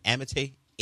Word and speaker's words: Amite: Is it Amite? A-M-I-T-E? How do you pronounce that? – Amit.